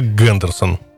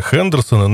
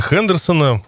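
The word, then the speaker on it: Henderson: Кстати сказать, некоторое количество таких мотоциклов в свое время попало в Россию, но по известному нашему пренебрежению к иностранным словам марка произносилась как Гендерсон.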